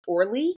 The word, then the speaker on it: Orly: But I believe it's pronounced Orly is the other airport that people tend to fly into as well.